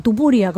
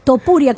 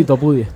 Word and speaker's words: Topuria: Tupuria, como digo yo. ¿Algún padre quiere que el niño sea Tupuria? Topuria, ¿cómo se llama el individuo? Siempre lo digo mal. – Sí, Topuria.